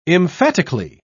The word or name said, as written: emphatically